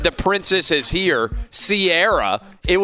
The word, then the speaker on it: Ciara: Well, what happened is when she got with Russell Wilson and sports people started talking about her, guys that didn't know music were calling her Ciara because of the way it's spelled.